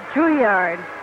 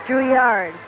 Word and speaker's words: Drouillard: It's not Drouillard without the D, is it? – It's Drouillard in French. Drouillard.